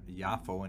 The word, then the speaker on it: Yafo: Today's Daf has a reference to an important location, Yafo. It's also known as Joppa, or pronounced Yopa, and also, of course, as Jaffa, like in the oranges, Jaffa oranges, or Yafo in Hebrew.